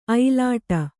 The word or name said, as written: ಐಲಾಟ